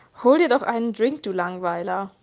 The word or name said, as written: Drink